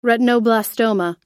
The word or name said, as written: retinoblastoma